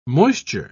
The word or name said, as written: moisture